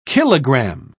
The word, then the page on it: kilogramme: British English